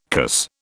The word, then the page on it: cuss: British English